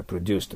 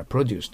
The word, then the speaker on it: produce: produce という名詞だったら頭にきます。動詞だったら produce となります。そこには発音記号 2つ書いてないけど、頭に入れておいてくださいね。名詞だったら produce と言います。